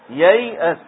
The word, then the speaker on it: ييأس: ييأس هذه جعلوا عليها السكون ما معنى السكون هذا أن هذا الحرف محذوف هذا الحرف لا ينطق زائد في الكلمة